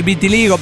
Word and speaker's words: vitiligo: Es el amigo que está con el tratamiento del vitiligio y está muy mal con el vitiligo. Perdón, sí, no sé si siempre lo pronuncio bien.